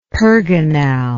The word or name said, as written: pergonal